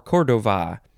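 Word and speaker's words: Córdoba: You have to emphasize the first syllable because it is spelled C-O-R-D-O-B-A, and the first O has an accent. It looks like it's down on the left and up on the right.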